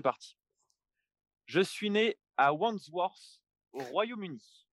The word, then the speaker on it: Wandsworth: Wandsworth.